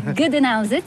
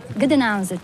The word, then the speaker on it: Gdańsk: Reprononce-le parce que... – Gdańsk, c'est le nom slave. Gdańsk. – Gdańsk, que nous, Français, prononçons en disant Danzig.